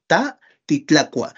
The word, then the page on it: ta titlakua: Náhuatl